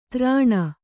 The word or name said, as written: ತ್ರಾಣ